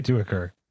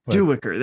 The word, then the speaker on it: Duiker: How do you say it? Because it's way cooler than me. – I say Duiker. – Duiker. That's so much better. – That's just my way of doing it.